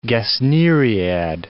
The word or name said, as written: gesneriad